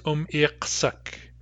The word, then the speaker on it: umirksuk: When a kinship word like umirksu takes the possessive ending uk or uk, it's always uk, and it's pronounced umirksuk. Umirksuk. This is a little unexpected because there's contraction going on. What we're doing is we're combining the stem umirksu with the ending uk, and when we pronounce them together, they contract to umirksuk.